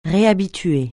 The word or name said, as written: réhabituer